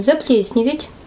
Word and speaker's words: заплесневеть: Действие наблюдаем заплесневеть, это правильное ударение на всякий случай, тоже еще подскажу.